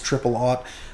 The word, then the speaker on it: triple aught: double or triple zero, usually pronounced triple aught, buckshot used.